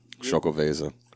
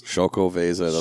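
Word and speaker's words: Xocoveza: Chocoveza. – So the letter X. – Chocoveza. The X is like a CH noise.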